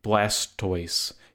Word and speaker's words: Blastoise: Because his name is mispronounced. Blastoise. You don't say tortoise, you say tortoise. It should be Blastus, but Blastus is a terrible name, so you go to the bottom, Sir Blastoise.